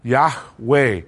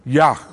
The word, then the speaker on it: Yahweh: But that is the name and that's the best guess on how to pronounce it. Yahweh. Or sometimes it's just short Yah, right? Yah, or, or, or, sometimes that Y is a little bit of a J, Yah, Yah, more like the, the, the Spanish uh, why.